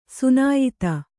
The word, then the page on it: ಸುನಾಯಿತ: sunāyita